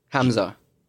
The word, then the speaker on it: Hamza: Am I even saying Hamza right? – Yeah, you're saying it fine. – You say it. – Hamza Jeetooa. Hamza.